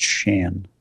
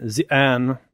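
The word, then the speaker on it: Xi'an: But whenever someone says her name, it's pronounced Shan. – Shan. Okay, you're... I like that better than Zian.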